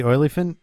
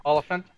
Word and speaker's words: Olyphant: So you find out in the beginning that she's a married housewife with Timothy Olyphant. Is that how you say his name? – Olyphant.